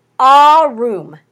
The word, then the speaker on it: Arum: Subtle means Arum is how you pronounce it.